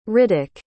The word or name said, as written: riddock